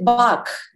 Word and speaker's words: Bach: Well, Bach, you pronounce it Bach, don't you? – Yeah, it's probably like. – You say Bach. – Bach. – The deep Bach.